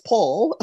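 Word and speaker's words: Paule: But here you will hear Paul Marshall, pronounced Paul, Paul Marshall, speaking about the civil rights movement and her thoughts on where the movement needs to go.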